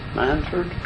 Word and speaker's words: Manford: Manford Forrest. – And you spell that M-A-N-F-O-R-D? – Just like it sounds. – All right. – Man and put Henry Ford's name on the end of it.